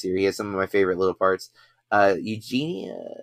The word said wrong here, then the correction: Eugenio: eugenio derbez that's his name